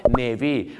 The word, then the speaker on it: navy: ะฮะแต่ภาษาอังกฤษไม่ใช่ print green นะฮะต้องเป็น the blueprint ครับเวลาจะสร้างตึกนะฮะก็ต้องมีพิมพ์เขียวก็คือ the blueprint ครับส่วนของผมเนี่ยนะครับเป็นสีน้ำเงินสีกรมท่าเขาเรียกว่า navy blue ครับอย่าลืมครับทหารเรือเขาเรียกว่า navy ไม่ใช่นาวีนะ